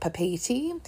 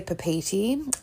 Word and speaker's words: Papeete: Now, Tahiti's capital is a place called, when I worked in a travel agency, we said Papeete, but they also pronounce it Papeete. So I'm just going to say Papeete.